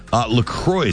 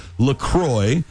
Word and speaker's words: LaCroix: Officially, it's pronounced LaCroix, so it rhymes with enjoy, not La Croix or La Croix, like the French might say it.